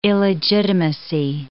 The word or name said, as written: illegitimacy